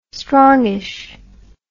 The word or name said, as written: strongish